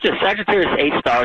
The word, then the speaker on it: Sagittarius A star: It's called Sagittarius A star.